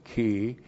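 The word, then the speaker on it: ki (כי): K long I, ki.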